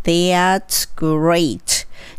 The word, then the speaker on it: That's great: この場合は th なので、ベロをちょっとだけ出して、 air の発音強く、 That's great なります。この great の場合は、 r をしっかり聞かせる。